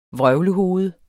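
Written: VRØVLEHOVED

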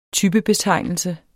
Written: TYPEBETEGNELSE

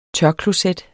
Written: tørkloset

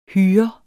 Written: hyre

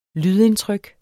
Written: lydindtryk

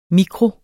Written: mikro

